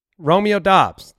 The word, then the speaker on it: Romeo Doubs: But if you go and listen, Romeo Doubs, that's the name. That's what he finally said it at a press conference after 4 years in Nevada not saying it.